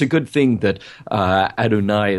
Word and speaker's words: Adonais: Oddly enough, Adonais rhymes with El Shaddai, one of the reasons that song might have been in there.